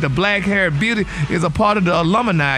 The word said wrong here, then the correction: Illuminati: Illuminati. The alumni, baby. Illuminati.